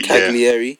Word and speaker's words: Cagliari: He did a good job with Caligari. – Calgary. – Calgary. Can't remember. And I'm mispronouncing that, so apologies. He did a decent job with them, and then moved to Milan. He was sacked. Cagliari.